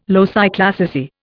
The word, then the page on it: loci classici: Indian